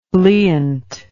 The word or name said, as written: bleaunt